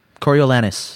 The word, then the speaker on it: Coriolanus: Coriolanus? How do they say in the... – Coriolanus. – Coriolanus Snow.